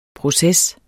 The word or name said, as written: proces